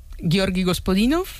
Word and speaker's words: Gospodinov: Ayúdanos a pronunciar, ¿no?, los autores. – A ver, Georgi Gospodinov, sí. Zdravka Eftimova y Vera Mutavcheva.